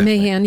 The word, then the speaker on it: Mahan: To your point, the tech bros all got behind Matt, is it Mahan? Mahan? How do you pronounce that? – Mahan, yeah.